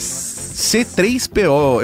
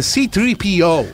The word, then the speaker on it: C-3PO: C-3PO, eu gosto da pronúncia em português. – É bonito, né? – Que em inglês é C-3PO.